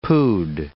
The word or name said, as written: pood